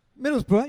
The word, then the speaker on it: Middlesbrough: how would you say Middlesbrough? – Middlesbrough, you got it.